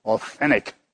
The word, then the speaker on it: authentic: You know, which you Americans say without the T, the second T, authentic.